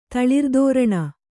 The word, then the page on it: ತಳಿರ್ದೋರಣ: kannada